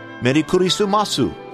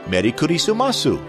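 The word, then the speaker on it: Meri kurisumasu (メリークリスマス): People just say it as an English word with Japanese pronunciation. And it goes something like this. Meri kurisumasu. Meri kurisumasu.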